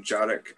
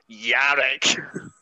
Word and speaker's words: Yarick: I'm just going to write down Yarick, because I've been calling him Jarick, and I just feel like a yurt every time I say that, so if I could say it wrong. – No. He'll appreciate me being like, it's Yarick.